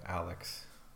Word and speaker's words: Alyx: I wonder if Alex, it's spelled A-L-Y-X, by the way, Alex,